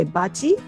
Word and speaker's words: boccia: Boccia? I mean, you, you say bachi? Um, I, we pronounce it different.